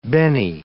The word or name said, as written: benny